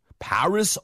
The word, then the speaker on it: Paris: 미국식 발음은 파리가 아니고 P-A-R-I-S, Paris. – 네, 여러분이 알고 있는 그 악동 있잖아요. Paris Hilton 할 때 그 바로 Paris 이 발음 똑같습니다.